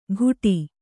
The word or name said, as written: ಘುಟಿ